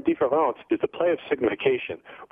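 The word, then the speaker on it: différance: And what différence means, and you'll note that the word is indistinguishable from the French word for difference, différence. The only difference between them is that the one word is spelled E-N-C-E, and the term différence is spelled A-N-C-E.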